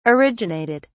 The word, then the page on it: originated: British English